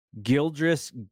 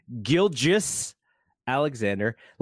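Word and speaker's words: Gilgeous-Alexander: Shai Gildress, G- Do you know how hard it is to not say Gildress when you say his name? It's incredibly easy. say Shai Gilgeous Alexander.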